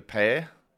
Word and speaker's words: Power: The Port Power, if you're in Adelaide and you speak to a South Australian, they use a one-syllable word for the word power. It's called Pair.